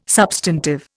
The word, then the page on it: substantive: Indian